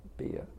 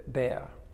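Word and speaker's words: Behr: Not Beer. B E H O. Bear.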